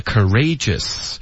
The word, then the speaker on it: courageous: 同时呢 courageous，它本身的这个字是什么发音，是 courage， OK，所以你就按照这个 G 的音后面加一个 us， OK，所以最主要的呢就是要看这个 G 的这个规律，是要看它有哪些拼写的方式是很常见的。